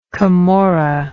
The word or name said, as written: camorrism